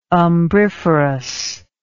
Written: umbriferous

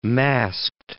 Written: masked

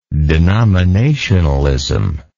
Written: denominationalist

